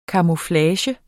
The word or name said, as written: camouflage